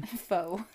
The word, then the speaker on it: faux: Now, I don't even know if that's classified as intimacy or a fox intimacy. – Faux. – No, fox. – No, faux. – No, it's spelled F-A-U-X. – Yes, but it's French and it's faux, pronounced faux, dear.